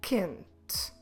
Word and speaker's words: quente: Q-U-E-I-J-O. Queijo. Let's think about another example. Quente. Hot. Quente. Como se escreve quente? Q-U-E-N-T-E. Quente. And again, we will not read the U. The U is there just to make the sound Q.